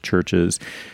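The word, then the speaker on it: CHVRCHES: One you can only find on YouTube of the band Churches, which, yes, I know is pronounced Churches, doing it with Robert Smith.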